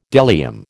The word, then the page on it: bdellium: American English